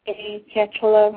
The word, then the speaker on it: Canchola: Reynolds, and mine is Melissa Cantola, my last name is spelled C-A-N-C-H-O-L-A, and you can check that out on our website, Truth Be Told Radio.